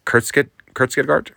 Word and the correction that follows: Kurzgesagt: Hold on, I'm trying. Kurtzgesagt. – Yeah.